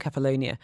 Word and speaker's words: Kefalonia: splitting our time between Kefalonia and Zakynthos. Am I saying that right? – Yes, you are.